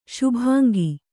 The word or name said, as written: ಶುಭಾಂಗಿ